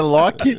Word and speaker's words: Loki: a lesão não é aprova a pronúncia, Loki. É, Loki.